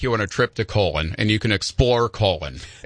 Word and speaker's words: Colon: Do you know that there's a town in southwestern Michigan called Colon? They literally pronounce it Colon, and it's the magic capital of the world.